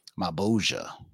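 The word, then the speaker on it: Malebolgia: Malbogia.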